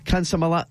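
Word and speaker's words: Consolmagno: So it's Father Guy Consolamagno. – Mm-hmm. – The G is silent in his name.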